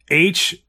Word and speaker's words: H.E.R.: her name is H.E.R. is pronounced her.